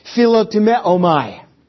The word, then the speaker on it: Philotimeomai: Philotimeomai.